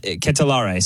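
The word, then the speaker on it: De Ketelaere: But they continue to go at De Calatare. I think is exactly how you pronounce it. But Ketelare, sorry, excuse me.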